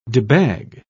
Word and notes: debag: British English

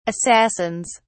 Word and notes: assassins: British English

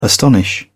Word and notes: astonish: British English

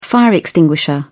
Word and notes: fire extinguisher: British English

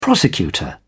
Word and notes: prosecutor: British English

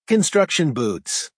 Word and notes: construction boots: American English

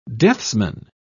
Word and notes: deathsman: British English

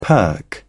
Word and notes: perk up: British English

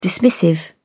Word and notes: dismissive: British English

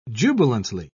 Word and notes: jubilantly: British English

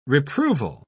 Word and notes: reproval: British English

